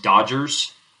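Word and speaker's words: DODGARS: The credential touches on FAR Part 35, DFARS Part 235, their PGIs, and it also touches on non-FAR-based regulations, such as the DOD GARS, pronounced Dodgers, DOD GAR, and a few other non-FAR-based policies, procedures, and instructions.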